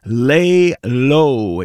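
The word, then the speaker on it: Leh-Lo: This is a cat from Toronto by the name of Lay Low. It's spelled L-E-H hyphen L-O.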